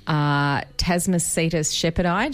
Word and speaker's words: Tasmacetus shepherdi: The paper is called New Diagnostic Descriptions and Distribution Information for Shepherd's Beaked Whale, Tasmacetus shepherdi.